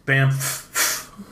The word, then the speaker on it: Banff: But we skied Lake Louise, and we, like, hiked around Banff a little bit. – I'm sorry, it's pronounced Banff.